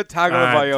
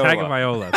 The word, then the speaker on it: Tagovailoa: Tua Valoga or whatever. I can't pronounce his last name. – Tua Valoga Tagovailoa. – Tagovailoa, that's what it is.